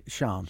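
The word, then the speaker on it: Sian: It's S-I-A-N, isn't it? – It's Sean.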